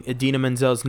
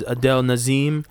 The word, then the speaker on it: Idina Menzel: 2014 gave us John Travolta mispronouncing Idina Menzel's name as Adele Nazeem. – Adele Nazeem.